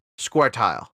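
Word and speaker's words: Scoia'tael: That word right there, Elderspeak, Squirtile. Like square, tile, like on the floor. It's the best pronunciation of it. Elderspeak sucks. – You want to spell it for the audience? – Squirtile is spelled S-C-O-I-A apostrophe T-A E-L.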